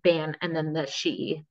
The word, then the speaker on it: sidhe: Like, so the she, which is spelled SIDHE in like Irish or Celtic or whatever, like means just like the underworld.